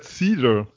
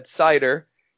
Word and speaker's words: Seider: you said Seider. So there's already a progress. – Yes. I wasn't sure which way was the proper pronunciation. And so, when Steve Yzerman called his name, he said Seider.